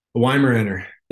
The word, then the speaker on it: Weimaraner: How do you pronounce the breed? – Weimaraner.